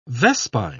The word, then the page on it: vespine: British English